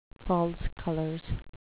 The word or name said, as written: false colors